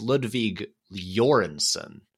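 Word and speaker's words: Ludwig Göransson: I was talking to someone, and apparently his name is pronounced it's Ludvig Jorgensen.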